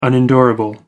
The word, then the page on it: unendurable: American English